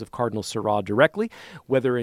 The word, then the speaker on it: Sarah: If you simply Google Cardinal Robert Seurat, S-A-R-A-H, like the name Sarah, but it's pronounced Seurat, and it's titled, Let Us Return to the Eucharist with Joy, September 3rd.